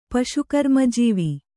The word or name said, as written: ಪಶುಕರ್ಮಜೀವಿ